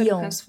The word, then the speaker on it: Lyon: Lyon.